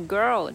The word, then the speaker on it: girl: Two little beautiful girls looking puzzled in a daze. 啊，最后补充一点，就是那个 girl 这个发音也是要拉长、拉饱满一点。它首先是舌头往后卷，girl，然后呢，舌头再往前抬，抬到你的门牙根部的后方，girl，girl，girl。